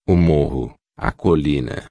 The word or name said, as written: morro colina